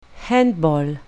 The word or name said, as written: handball